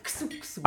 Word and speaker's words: Xuxo: Deutsche Leute, Aussprache. Wir trinken Xuxo Espresso. Xuxo. Aber sag bitte, du kennst auch Xuxo, kannst du.